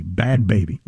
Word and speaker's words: Bhad Bhabie: It's spelled B-H-A-D-B-H-A-B-I-E, Bad Baby.